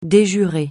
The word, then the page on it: de jure: francais